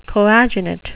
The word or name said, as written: coadunate